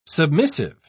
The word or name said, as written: submissive